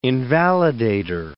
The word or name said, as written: invalidator